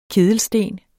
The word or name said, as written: kedelsten